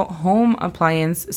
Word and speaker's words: home appliance: En este caso, home appliance significa aparato de casa o electrodoméstico, como los conocemos. La pronunciación fonética de home es J-O-U-M, home. Appliance es A-P-L-A-I-A-N-S.